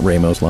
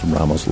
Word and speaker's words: Ramos: Hey, I want to give a quick shout-out to Ramos Law, and I am pronouncing that correctly. It's not Ramos Law, it's Ramos Law,